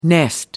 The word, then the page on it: nest: American English